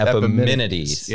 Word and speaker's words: Epimenides: This is a, a Greek uh, philosopher, uh, is Epidemides? Is that how you say it? Or Epimenides. – Epimenides. – Epimenides. Yeah. Okay.